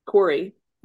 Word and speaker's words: Corey: So my name, Corey, which is spelled C-O-R-E-Y, is a male-female name.